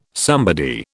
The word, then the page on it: somebody: British English